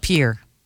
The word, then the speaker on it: Pierre: Pierre.